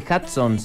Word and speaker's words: Hudson: Se dice Hudson, se dice Hudson, se dice Hudson. Hay pronunciaciones de lo más variado.